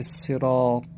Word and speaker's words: صراط: این کلمه صراط با طاء مؤلفه، یعنی طاء دو نقطه و منقوط نیست، طاء الف داره. برخی برای اینکه این طاء رو درست تلفظ کنن شبیه دال تلفظش میکنن.